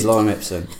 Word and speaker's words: lorem ipsum: It is lorem ipsum.